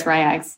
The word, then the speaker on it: RY X: Thank you for finally telling me how to say his name, Ryax. I'm like, R-Y-X. – I'm pretty sure it's Ryax.